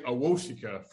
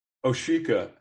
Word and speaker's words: Awosika: I've been saying Awosika for a long time, but it is not correct. It is Oshika.